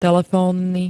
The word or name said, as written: telefónny